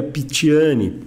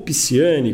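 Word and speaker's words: Picciani: Por exemplo, o grupo da família Pitiani, ou Pisciani, como se diz aqui em Brasília, sem respeitar aí a pronúncia correta da língua italiana.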